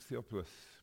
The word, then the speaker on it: Theophilus: most excellent Theophilus. That's how you pronounce it.